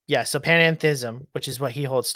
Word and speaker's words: panentheism: So you have pantheism and you have panentheism. The end there is different.